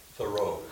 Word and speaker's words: Thoreau: Thoreau.